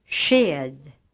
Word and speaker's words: sked: SK before a soft vowel is pronounced like a sh sound in English, shoe, shine, etc. Listen to these examples. – Sked.